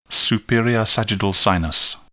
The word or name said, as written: superior sagittal sinus